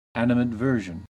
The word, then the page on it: animadversion: American English